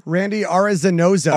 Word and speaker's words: Arozarena: Randy Arozarena.